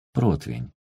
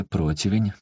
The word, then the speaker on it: противень: Наконец, доберемся мы и до странного слова «противень», которое хозяйки часто произносят неправильно «противень». Нет, все-таки «противень», давайте это запомним.